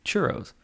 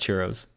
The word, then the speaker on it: churro: Now, I know I sound like the whitest person in the world when I say churro, but that's how I say it. It's only because you can't roll your R's.